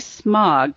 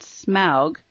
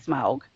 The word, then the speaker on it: Smaug: Our conundrum is, will Smaug, and I hope I said that right, because I know once in a while I say smog, but I mean Smaug. So that's my Midwestern accent coming out.